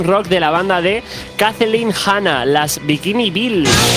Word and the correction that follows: Bikini Kill: Lo he dicho mal, ¿eh? Las Bikini Kill.